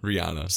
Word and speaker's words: Rihanna: What is it? Rihanna? – Rihanna. – So the H is silent.